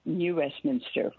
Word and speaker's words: New Westminster: New Westminster, which is near Vancouver, a suburb of Vancouver.